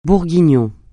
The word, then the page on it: bourguignon: francais